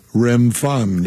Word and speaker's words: Remphan: I've never pronounced this word, "Remphan." Is that correct? – Remphan, yes.